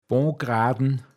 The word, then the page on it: Poistaziach: Pinzgauer Mundart